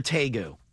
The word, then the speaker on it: tegu: But is it a tegu or a tegu? – I think it's tamo. – No. These actually do something. – The internet says T-E rhymes with meh. – Oh, tegu. – And G-U sounds like goo.